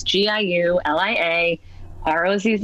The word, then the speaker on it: Giulia: The Italian way, so just I hate to use the example of Mayor Giuliani, but it's spelled that way. It's Giulia Rossi on Twitter,